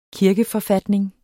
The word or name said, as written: kirkeforfatning